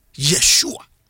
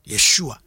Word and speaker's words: Yeshua: Na Hebrew fo no omo eni letter J. Enti ni ye je bi omo pronounce no. Ye Yeshua. Yeshua.